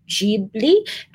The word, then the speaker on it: Ghibli: It's called Studio Ghibli.